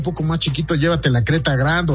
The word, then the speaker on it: Creta Grand: Empieza en 451,000 pesos, y está, recuerden que está la Creta y la Gran Creta, y además tienen, o bueno, Creta Gran, para decir bien el nombre como se escribe. Creta Grand, y además traen los motores turbo.